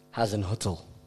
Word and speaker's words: Hasenhüttl: Is it Raf Hasenhüttl? – Hasenhüttl. – That's his name.